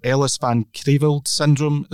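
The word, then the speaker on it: Ellis-van Creveld syndrome: Your particular disability, Brendan, so forgive me if I pronounce this wrong, but Ellis Van Creveld syndrome, is that right? – Yeah. Excellent. – You hit it in one, that's it. Brilliant, 10 out of 10 for pronunciation, if nothing else.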